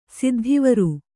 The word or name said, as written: ಸಿದ್ಧಿವರು